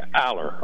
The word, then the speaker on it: Allar: Allar.